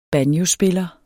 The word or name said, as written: banjospiller